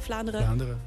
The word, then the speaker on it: Vlaanderen: Zeg ik dat goed? Vlaanderen. – Vlaanderen, ja.